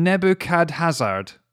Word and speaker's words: Nebuchadnezzar: Eden Nebuchad. – I said that. I put one too many syllables in there. Eden Nebuchadnezzar.